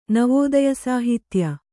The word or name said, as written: ನವೋದಯಸಾಹಿತ್ಯ